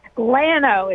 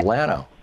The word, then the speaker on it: Llano: Llano. It's spelled with two L's. – Ah, okay. Llano. – L-L-A-N-O. Yeah.